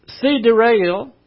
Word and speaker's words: sidereal: An event, huge events happened between verse 1 and verse 2, which did not affect the sidereal. This is not sidereal. Sidereal is the way it's pronounced.